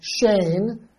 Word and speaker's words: Shane: Like my name is Shane. But a lot of people say Shin.